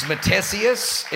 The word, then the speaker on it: Matthesius: Now, my last name is Matthesius. It looks like a spelling test. Don't even try and pronounce it.